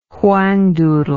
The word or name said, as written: whangdoodle